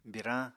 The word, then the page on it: birañ: Apatani